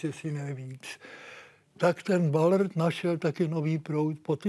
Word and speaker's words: Ballard: píše se Ballard, čte se Bob Ballard,